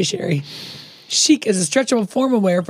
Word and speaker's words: Cheek: We can do a lot of cross promotion between our shows and your clothing brand, Cheek. – It's pronounced chic. It's French. – But it's spelled C-H-E-E-K.